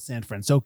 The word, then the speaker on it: San Francisco: And then because it was called San Francisco, I think I said that right.